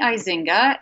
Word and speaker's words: Eizenga: Yes, it's Penny Eizinga. And my last name is spelled kind of like Eisenhower, Eizenga.